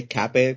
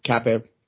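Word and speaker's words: Kape: Is it Cape or Cape? – I would say Cape, considering he's from Portugal, but yeah, I would say Cape personally. – Yeah, I'll say Cape too.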